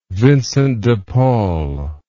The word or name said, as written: Vincent de Paul